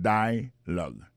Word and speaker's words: dialogue: Alors mot dialogue ça c'est dialogue. Ça ne carrément que là, nous carrément que l'accent tonique là tombé sous premier syllabe là.